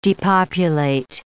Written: depopulate

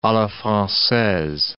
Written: a la francaise

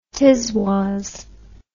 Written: tiswas

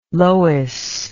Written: Lois

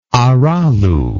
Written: arallu

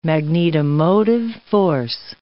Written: magnetomotive force

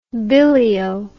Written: billyo

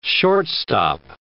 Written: short-stop

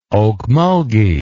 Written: okmulgee

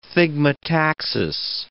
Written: thigmotaxis

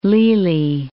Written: Lely

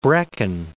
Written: Brecon

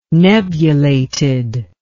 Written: nebulated

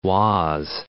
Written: Oise

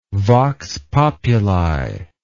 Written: vox populi

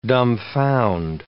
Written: dumfound

